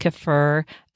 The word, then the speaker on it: kefir: Now, fermented foods include things like yogurt, not all yogurt's created equal, but quality yogurt, kefir, also pronounced kefir, kombucha, also pronounced kombucha, sauerkraut, I think there's just one pronunciation for that, kimchi, you know, lots of fermented foods from all over the world.